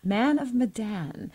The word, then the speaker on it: Man of Medan: Man of Medan, which has been described as, quote, interactive storytelling.